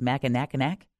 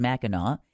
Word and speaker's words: Mackinac: You don't know if it's Mackinac, but it's Mackinac.